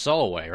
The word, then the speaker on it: Samhain: Do you think like right before maybe filming started or somewhere during post-production, someone went up to Doherty and went, you know, it's pronounced Solway, right? – What? – Then he stabbed that man. I am on team Samhain.